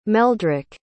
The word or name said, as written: meldrick